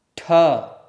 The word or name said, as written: ṭh